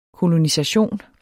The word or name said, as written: kolonisation